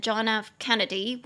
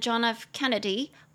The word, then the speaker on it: John F. Kennedy: 我就帮大家补充一句，顺便学一下那个亚伯拉罕林肯和约翰甘乃迪他们的全名怎么发音，你就可以说 Abraham Lincoln and John F. Kennedy were both assassinated. Abraham Lincoln and John F. Kennedy were both assassinated.